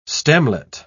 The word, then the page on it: stemlet: British English